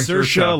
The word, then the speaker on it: Saoirse: Saoirse like inertia.